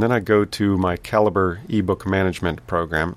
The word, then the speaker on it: Calibre: That's Caliber spelled C-A-L-I-B-R-E. It looks like Calibre, which would make sense. I mean, the word Libre implies books, but whatever. I think it's supposed to be pronounced Caliber.